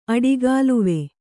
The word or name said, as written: ಅಡಿಗಾಲುವೆ